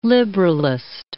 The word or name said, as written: liberalist